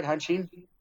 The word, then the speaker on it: Hanshin: But it was following the 1982 season, Randy Bass decided he's going to Japan, signed with the Hanshin Tigers. Did I say that right, Hanshin? – Yep.